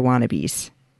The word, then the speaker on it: Wa-Nabi: So I still would give it a 7 out of 10 Queen Whatever I Wanna Bees. – Nice. – Or Wannabe. I put the emphasis on the wrong syllable.